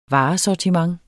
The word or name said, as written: varesortiment